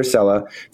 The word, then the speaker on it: Sela: Ilir Sela, founder and CEO, Slice.